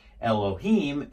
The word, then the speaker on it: Elohim: Well, in Aramaic, it's the exact same equivalent word, just instead of pronouncing it Elohim, it's pronounced Elahin, and it's, you know, you can see how close that is.